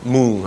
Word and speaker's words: Moong: And he knows Do Swan Mung. Moong, I'm sorry. Moong is the way you pronounce it. M-O-O-N-G is the way it's pronounced.